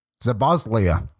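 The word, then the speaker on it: Szoboszlai: Dominic Sablaz. Oh my goodness, why am I even trying to say his name? You know who I mean. Zaboslia. That's how you say it.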